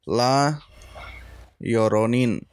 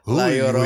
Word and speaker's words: La Llorona: But we're going to talk about La Llorona. Oh, I butchered the fuck out of that. – Wow. La Llorona.